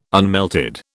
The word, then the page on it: unmelted: British English